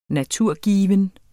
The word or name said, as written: naturgiven